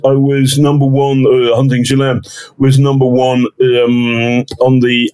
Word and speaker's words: Ghislaine: I can never say her name properly. Hunting Ghislaine. – I call her Ghislaine.